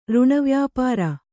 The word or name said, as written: ಋಣವ್ಯಾಪಾರ